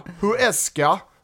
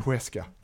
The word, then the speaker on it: Huesca: Huesca, 0-0.